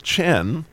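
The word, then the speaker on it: chen (חן): which is chen, if you were to pronounce that in Hebrew.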